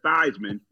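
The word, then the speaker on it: Theismann: So he changed his name from Thiessman to Thiessman to rhyme with Heisman, okay? – It's perfect.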